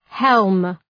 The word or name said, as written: helm